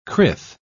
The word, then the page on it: crith: British English